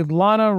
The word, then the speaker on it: Lana: Esteban G, DJ Kento, Garrett W, Kimberly L, Tom N, Tony V, Rodolfo V, Jason W, Osama A, Lana rhymes with banana,